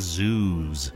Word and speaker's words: Zeus: I've been pronouncing Zeus wrong. Apparently, it is Zeus.